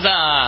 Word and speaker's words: SZA: Is that how you pronounce that? S-Z-A? I don't know. – Siza. – Siza. Siza. Okay.